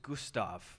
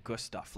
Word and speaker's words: Gustav: My name is Gustav. I usually pronounce it Gustav, like good stuff.